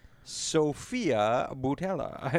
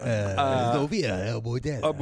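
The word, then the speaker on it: Sofia Boutella: asking my thoughts on Sophia Boutella, and she plays the original mummy. – Nice pronunciation. – Sophia Boutella.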